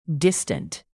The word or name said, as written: distant